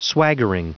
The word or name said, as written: swaggering